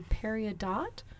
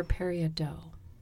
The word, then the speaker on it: peridot: But it's called, people pronounce it differently depending on where you live, but it's called a peridot or a peridot. Probably in French, it's a peridot.